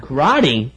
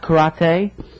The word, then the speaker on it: karate: Karate? – Yes. – I think, isn't it pronounced karate? – Karate, you say karate, I say karate.